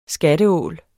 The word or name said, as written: skatteål